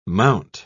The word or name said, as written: mount